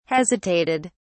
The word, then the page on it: hesitated: British English